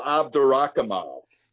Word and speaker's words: Abdurakhimov: And maybe you can help me pronounce this guy's name, Abdurakhimov, or something. What's going on in that fight? – Shamil Abdurakhimov. – Yeah, that's what I was trying to say.